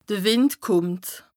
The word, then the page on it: de Wìnd kùmmt: Bas-Rhin; Strasbourg